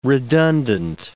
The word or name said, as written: redundant